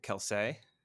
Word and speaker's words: Kelce: from what I understand, it's just Kels is how it's supposed to be said. but we've just been saying his name wrong the entire time. – Travis Kelce? – Yeah, it should just be Kelce.